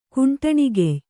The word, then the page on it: ಖಂಡಣೆಗೆಯ್: khaṇḍaṇegey